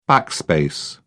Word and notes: backspace: British English

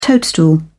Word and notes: toadstool: British English

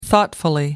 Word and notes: thoughtfully: British English

